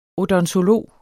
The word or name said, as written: odontolog